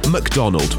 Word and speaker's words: MacDonald: It's called McDonald. That's spelled M-A-C-D-O-N-A-L-D.